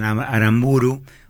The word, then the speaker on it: Aramburu: Pierre Guérin, amigo de Federico Martín Aramburu, algunos dicen Aramburu, otros dicen va el acento en la U final,